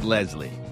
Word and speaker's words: Leslie: she got as close as she could with Leslie. Rhymes with Wesley.